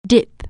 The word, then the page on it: dip: anglais